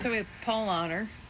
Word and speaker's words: Paulaner: in English, you'd pronounce it Paulaner. In German, they pronounce it Paulaner. – Paulaner. – P-A-U-L-A-N-E-R.